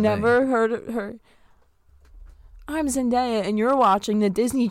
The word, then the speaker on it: Zendaya: Please don't tell me you actually pronounce it Daya. – So I said it right. – No. – No, you don't. It's Zendaya.